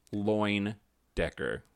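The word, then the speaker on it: Leyendecker: Loindecker. – I even looked up the pronunciation because it's spelled like something completely different.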